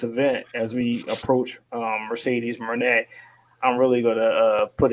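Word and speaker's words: Moné: Mercedes Monet is how she wants it pronounced, I've heard.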